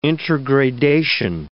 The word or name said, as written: intergradation